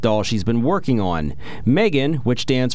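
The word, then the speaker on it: M3GAN: Is it pronounced Megan? – Megan, yes.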